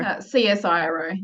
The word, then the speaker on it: CSIRO: So is it Cisco Publishing? Is that how you say it? Or CSIRO. Yeah. – Okay. Not right at all, but yeah.